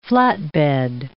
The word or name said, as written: flatbed